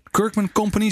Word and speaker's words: Kirkman Company: Maud Schaapveld, je bent consultant bij Kirkman Company, zeg ik dat goed? – Ja, dat zeg ik goed.